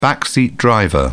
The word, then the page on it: backseat driver: British English